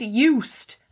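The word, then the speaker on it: Joost: I mean, Juice the other day, sorry, Jost, what have we decided to call him now? He said it rhymes with toast, doesn't he?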